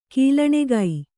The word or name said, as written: ಗೊಲ್ಲಣಿಗೆ